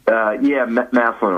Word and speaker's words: Massillon: And then your dad, who coached our rec basketball team back in the day, he hails from, I'm going to mispronounce it, Massillon, Ohio, near Cannon? – Massillon, Ohio.